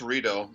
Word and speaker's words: Rideau: It's pronounced Rideau, I mean, from an Anglo sense.